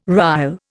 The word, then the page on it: rille: Indian